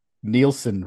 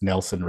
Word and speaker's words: Nielsen: So, in our long shot episode, I mispronounced Nielsen ratings as Nelson ratings.